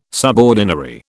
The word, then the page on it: subordinary: British English